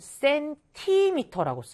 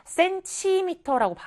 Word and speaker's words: 센티미터: 자, 쓸때 여러분들 센티미터라고 쓰셔야 돼요. 우리 가끔 이거를 발음할 때 센치미터라고 발음을 하는 친구들도 있어요. 자, 그런데 우리가 정확한 정식 명칭은 무엇이에요? 센티미터.